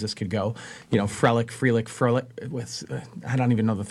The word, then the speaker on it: Frelick: Sal Frelick, am I saying his name right? – It's Frelick. Yes.